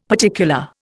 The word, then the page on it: particular: Indian